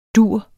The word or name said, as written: dur